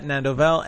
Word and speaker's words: Nandovel: You can find me on Twitter at Nandovel, NANDOVEL. – Yep, I think I said that right. – That's how I spell my name? – That's how I spell my name.